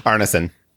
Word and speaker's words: Arneson: Arneson.